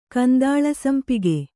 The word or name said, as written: ಕಂದಾಳಸಂಪಿಗೆ